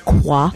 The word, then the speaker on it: Coi: A restaurant which has the name that's spelled C-O-I, but is pronounced Qua.